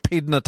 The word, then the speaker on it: Pydna: The Battle of Pydna. Have I said that right, Harold? – That's right.